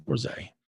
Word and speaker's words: Bourzat: I believe her last name is Bourzat, B O U R Z A T. Francois Bourzat, Bourzat Bourzay, Um, and she speaks about darkness in her journeys through psychedelic medicines.